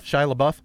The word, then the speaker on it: Shia LaBeouf: You're a LaBeouf? Is that how you say his name? – Shia LaBeouf.